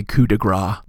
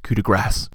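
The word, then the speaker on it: coup de grâce: It's like when people say coup de grace. It's coup de grace. C-E is pronounced S.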